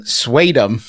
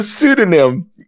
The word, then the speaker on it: pseudonym: I have a friend who is intelligent and well-read, but for reasons unknown, she mispronounces the word pseudonym, saying swadum. – Oh, man. – Instead of pseudonym.